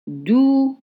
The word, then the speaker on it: doucement: It's spelled doucement, but the right pronunciation is doucement. Doucement. Let's study the pattern. C, E, M, E. The E is dropped. Doucement.